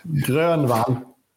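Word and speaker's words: Grönwall: But I think you pronounce it, what is it, Eric Grunwald? Grunwald? No, there you go. He's laughing his head off. – You're Grunwald. – There you go. That's the proper pronunciation.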